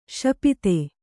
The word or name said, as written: ಶಪಿತ